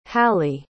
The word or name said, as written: hallie